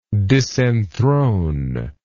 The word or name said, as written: disenthronement